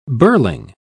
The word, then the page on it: birling: British English